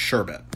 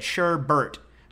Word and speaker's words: sherbet: I mean, it's sherbet. – Okay. – That's how it's spelled. There's no yeah, back there. – I know that's how it's spelled, but I have always, my whole life, since I used to order it in from the 70s on, have said sherbert, like Bert and Ernie.